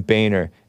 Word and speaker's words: Boehner: According to a LinkedIn profile, worked for John Boehner, spelled Boehner. So some of us thought that it was pronounced differently, but it's pronounced supposedly John Boehner.